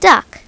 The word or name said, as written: duck